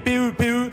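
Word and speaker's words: Pepe: Alors ça s'écrit PP, ça s'écrit PP, PE, PE, mais ça se prononce Pep évidemment.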